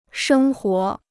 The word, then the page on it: 生活: shēng huó